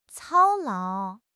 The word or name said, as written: 操劳